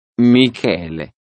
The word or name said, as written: michele